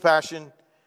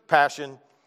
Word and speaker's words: passio: And the way I want to attack our conversation is by unpacking four statements: God's all-consuming passion, the early church's passion, our all-consuming passion, and praying for one. Now, the word passion comes from the Latin word passio, and I said that right. I looked it up online, passio, right, which means to suffer.